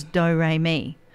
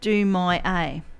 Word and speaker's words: Do Re Mi: But the other morning I did make a big boo-boo with a song that I was playing, and I didn't realise it was Do Re Mi. So I pronounced it Do My A.